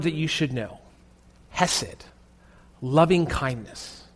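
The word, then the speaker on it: hesed (חסד): In Hebrew, it's pronounced hesed.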